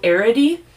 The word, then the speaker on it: Arridy: I had a hard time, and I finally looked up how to say his name. This case is about Joe Arridy.